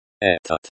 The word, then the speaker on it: этот: Этот. Pronounced этот, like этот, with the emphasis on the first syllable, means this, and is an adjective, which agrees in gender and number with the noun dispute.